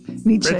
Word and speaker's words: Nietzsche: Nietzsche, yeah.